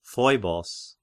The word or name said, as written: Phoibos